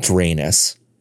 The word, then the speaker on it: Drainus: Dr- called Drainus. – Spell it. – D R A I N U S. – Like drain and anus.